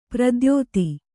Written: ಪ್ರದ್ಯೋತ

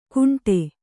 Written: ಕುಂಟೆ

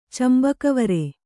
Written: ಚಂಬಕವರೆ

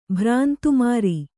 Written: ಭ್ರಾಂತುಮಾರಿ

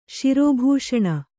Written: ಶಿರೋಭೂಷಣ

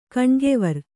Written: ಕಣ್ಗೆವರ್

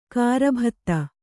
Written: ಗರ್ಬಿತ

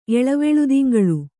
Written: ಎಳವೆಳ್ದಿಂಗಳ್

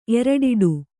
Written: ಎರಡಿಡು

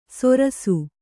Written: ಸೊರಸು

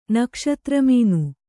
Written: ನಕ್ಷತ್ರಮಾನ

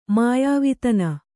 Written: ಮಾಯಾವಿತನ

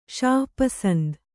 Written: ಷಾಪಸಂದ

